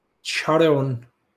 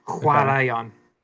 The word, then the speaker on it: chwaraeon: E-O-N. – C-H-W-A-R-A-O-N. – E-O-N. E-O-N. – Charon. – It's a good guess. It's the classic sound at the beginning of it. Huarayon.